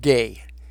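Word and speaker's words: gay (γῆ): It's spelled G-E, in Greek pronounced gay, and literally means the world.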